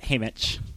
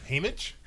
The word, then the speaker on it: Haymitch: Across from me, we have Haywitch. – Hey, I believe it's called pronounced Haymitch. – Dang it. Oh, that's an M, not a W. It's across from me, we have Haymitch.